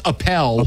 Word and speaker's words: Appel: 2013 MLB draft, Mark Apple. – It's Appel. – Appel.